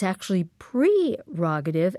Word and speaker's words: prerogative: Now, most of us know it's spelled P-R-E, and we just kind of smush it into P-E-R, prerogative. But you say it's actually prerogative, and sure enough, we went to Merriam-Webster.